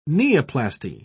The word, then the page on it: neoplasty: British English